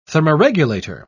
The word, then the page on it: thermoregulator: British English